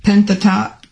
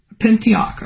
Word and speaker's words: Pentateuch: How did you say that word? – Pentateuch. – Pentateuch, okay. Okay, I would have never got that right.